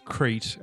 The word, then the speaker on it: Crete: It's pronounced Crete, and it's an island in Greece. He's saying it wrong.